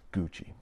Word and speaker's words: Gucci: It's Gucci. That's how Americans pronounce that Italian brand.